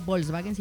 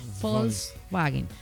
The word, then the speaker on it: Volkswagen: Hablando de sí, porque no se dice Volkswagen, sino Volkswagen, por si algo.